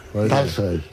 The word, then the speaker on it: Tolstoy: Que se escriben en Tolstoy. – O. Las palabras que Tolstoy. Se escriben en O en ruso, pero van acentuadas, se pronuncian casi A.